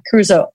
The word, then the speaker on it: Caruso: Curse, curse. Oh my God, why Caruso. can't I say his name? – Yeah, Caruso.